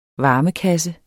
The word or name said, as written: varmekasse